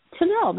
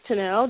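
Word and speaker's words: Tunnell: On Instagram, actually, on all of my social medias, just like Matthias for makeup, I am at Denise Tennell. That's two N's and two L's. – And I am Janice Tennell, Janice spelled like Janice, J-A-N-I-C-E, last name Tennell, two N's, two L's. – And it's T-U-N-N-E-L-L? – Yes.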